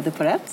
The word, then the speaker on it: de Poret: Jag tycker faktiskt att man ska säga Emilia de Porrette. – Ja, precis. Om man uttalar tet.